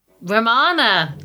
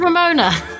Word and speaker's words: Romana: And the winner is... Romana. – Yes. I mean... We... – Sometimes Ramona. – Sometimes, yes. I'm glad you got her name right.